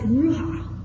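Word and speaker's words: ruach: And I've always pronounced it ruha. It's just a really neat word, ruha.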